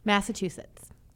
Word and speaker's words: Massachusetts: So first off, we're going to talk about Massachusetts. – Cool. – Is that the right way to say it? – Massachusetts.